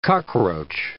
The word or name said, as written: cockroach